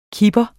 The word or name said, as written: kiper